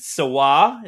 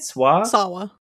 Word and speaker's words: Sawa: Right hand becomes possessed and with murderous intent starring Devin Sawa. Is that how you say it? – Sawa. – Sawa and Seth Green.